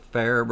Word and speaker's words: Farrar: Is that pronounced like the Farrah brothers here? – It is, mm-hmm. F-A-R-R-A-R.